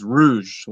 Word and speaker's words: Rouge: Someone told me that when he like first started, when he was super young, uh, he was Rouge, like the Rogue, but you know, uh, and then they just changed it to Rouge, but kept the same pronunciation.